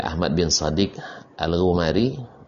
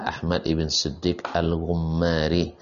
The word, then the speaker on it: Ahmad ibn Siddiq Al-Ghumari: Dan sebagian orang membaca Ahmad bin Siddiq Al-Ghumari, yang benar adalah Ahmad ibn Siddiq Al-Ghumari.